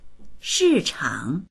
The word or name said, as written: shi4chang3